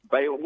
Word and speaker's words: Balaam: I've heard it pronounced both ways, and you know, it's Balaam. We'll say Balaam for sake of argument.